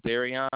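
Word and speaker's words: Darrion: My first name is Darion.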